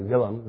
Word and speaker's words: Willem: I call him William. It's actually Willem. If you want to pronounce that V in the Dutch.